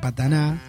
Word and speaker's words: Patna: En realidad nos vamos al encuentro de una cantante que, bueno, nació en Patna, ahí en la India, pero en realidad se dedicó, siempre vivió en Canadá. Ella nació en Patna, en hindi se pronuncia Pataná, sí, Patna.